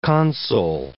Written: console

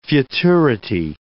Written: futurity

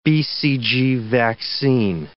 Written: BCG vaccine

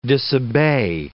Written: disobey

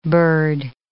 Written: bird

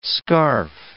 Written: scarph

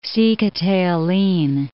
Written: Sikhote-Alin'

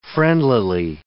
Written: friendlily

